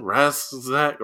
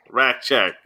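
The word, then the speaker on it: Rasczak: How do you say his last name? Rask-Zack? – Rackcheck.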